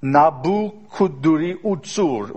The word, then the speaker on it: Nebuchadnezzar: Nabucodonosor would be that the kind of a Babylonian pronunciation. But of course, the Hebrews couldn't pronounce that, so they would, you know, Hebrewize everything. Nebuchadnezzar, right?